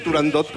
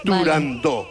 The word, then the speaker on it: Turandot: pues yo también lo digo mal y digo Turandot como todo el mundo. Pero para que quede claro. – Entonces, todos tontos ya. – Para que quede claro, es Turandot.